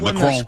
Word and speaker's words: Macron: And then Keir Starmer got together with Macron. You have to say it like that. Macron, when they got together, they're like, no, everything is good.